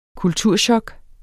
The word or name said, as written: kulturchok